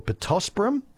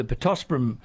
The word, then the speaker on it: Pittosporum: I think the Pittosporum, or Pittosporum as it's more correctly pronounced. – Sorry. Really? – Yeah, no, Australians always say Pittosporum, but we had an English garden guest lecturer come out when I was at Burnley, and he was outraged. He said it's Pittosporum. – Right. – Because Latin has no emphasis on any of the syllables.